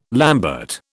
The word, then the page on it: lambert: British English